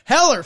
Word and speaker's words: Heller: Jerry Hellier. Heller. Fuck. – Yeah, I was like, you kept saying with a D, and it was bothering me. I was that's not his name. It's Heller.